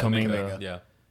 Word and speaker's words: Kuminga: Kuminga.